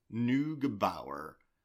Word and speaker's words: Neugebauer: I, I researched how to say her name. Neugebauer.